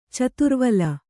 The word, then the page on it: ಚತುರ್ವಲ: Kannada